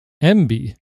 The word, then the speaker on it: Emby: And I believe you pronounce this MBY. It's spelled EMBY. What do you think of the new name for media browser? – Yeah, that would be my guess is MBY.